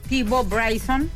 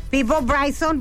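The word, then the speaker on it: Peabo Bryson: Ay, ¿cómo se dice eso? ¿Piavo? Esa palabra. – Peebo Bryson. – Peebo Bryson, bueno...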